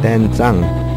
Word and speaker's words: Dan Tsang: Dan Tsang.